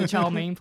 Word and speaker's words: Chalamet: How do you say his last Chalamet? – Name? – Chalamet. – Chalamet. – Chalamet. – Chalamet. – Sure. Say me when I get a Chinese. – Chalamet God. – I'll have the Chalamet, please.